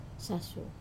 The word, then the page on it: sasu: Wamesa